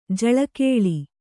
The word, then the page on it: ಜೋಲುಗಾಲು: jōlugālu